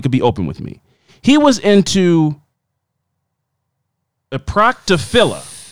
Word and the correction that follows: proctophilia: A proctophilia.